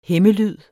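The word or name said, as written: hæmmelyd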